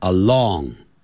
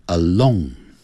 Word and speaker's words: alone: 那句尾的 alone 好变成 along，要念成 alone，独自的，单独的 alone 啊。